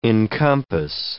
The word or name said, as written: encompass